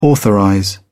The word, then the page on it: authorize: British English